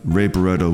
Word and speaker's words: Barretto: And we started the set with Ray Barretto, and I'm just pronouncing it as I know how to say it, Ray Barretto. I'm not rolling my R's, sorry, but Ray Barretto with The Teacher of Love,